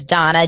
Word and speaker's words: Dana: So my name is spelled D-A-N-A. It's hard to, understand, but it's Donna, D-A-N-A. So I'm on Instagram at This Is Donna Bowling, same at TikTok.